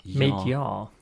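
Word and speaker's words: Mid-Yare: So they were introduced initially to four sites in Norfolk and Suffolk Broads, including RSPB Mid-Yar, I think that's how you pronounce it, Y-A-R-E, Mid-Yar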